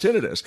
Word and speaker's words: tinnitus: It's your tinnitus, actually pronounced tinnitus.